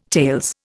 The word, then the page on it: tales: Indian